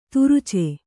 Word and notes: ತುರುಚೆ: turuce

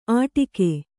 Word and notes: ಅಡಿಕೆ: Kannada

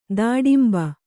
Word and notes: ದಾಡಿಂಬ: dāḍimba

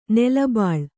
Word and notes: ನೀಲಿಬಳ್ಳಿ: nīli baḷḷi

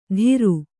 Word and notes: ಧಿರು: Kannada

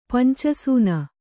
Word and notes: ಪಂಚಸೂನ: panca sūna

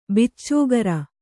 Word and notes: ಭಚಕ್ರ: Kannada

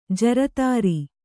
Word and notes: ಜೋರುದಾರಿ: jōrudāri; kannada

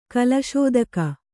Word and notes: ಕುಲಸೂತಕ: kulasūtaka

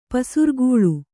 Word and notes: ಪಸಿರ್ಗಲ್: kannada; pasirgal

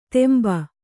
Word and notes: ತೆಂಬ: kannada

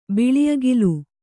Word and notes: ಬಿಳಿಅಗಿಲು: Kannada

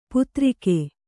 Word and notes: ಪುತ್ರಿಕೆ: putrike

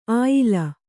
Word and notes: ಆಯಾಲ: āyāla

